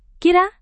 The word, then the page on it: kira: Indonesian